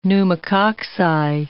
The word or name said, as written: pneumococci